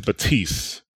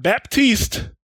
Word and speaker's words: Batiste: Not Baptiste, Batiste. Batista. And we normally pick the names of these episodes like after the fact. The name of this episode is going to be The P is Silent because it is not Baptiste, it is Batiste.